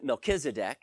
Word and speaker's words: Melchizedek: We pronounce it Melchizedek.